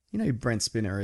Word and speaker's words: Spiner: And it's Spiner. If we're getting into pronunciation, it's Spiner. – It's definitely not. That's Spinner. – No, it's not. The two N's is Spinner. – Yeah, that's how you spell it. Let's look it up. – Brent Spiner. Data from Star Trek. – Yeah, I know. – It's Spiner. One N.